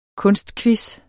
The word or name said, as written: kunstquiz